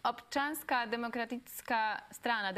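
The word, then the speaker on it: Občanská demokratická strana: And obczanska demokratyczna strana, did I pronounce it Czy wymówiłam to dobrze? – You pronounced great.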